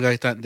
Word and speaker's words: Gaitan: Like, if, for example, I go to the class and be like, oh, hi, my name is Israel Gayton and blah, blah, blah. He'd be like, no, say it right. And I'm like, what? My name is Israel Gayton. No, say it right. Israel Gaitan. There you go.